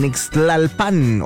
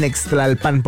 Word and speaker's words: Nextlalpan: Así es que por allá te vamos a esperar con todo el flow, con todo el sonido de la salsa en Nextlalpan. Oye, casi casi rimó, casi casi, a excepción del Nextlalpan, porque no se pronuncia así. Es Nextlalpan.